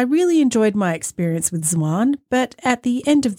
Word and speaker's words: Zwan: Yeah, Zwan.